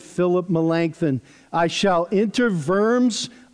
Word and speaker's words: Worms: It all culminated in his dramatic stand at the, as Jeff has told me how to pronounce this, Diet of Worms. Looks like Diet of Worms, which could be misunderstood.